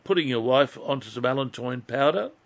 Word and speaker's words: allantoin: allantoin. Now, I'll spell it for you. A-L-L-A-N-T-O-I-N. Allantoin.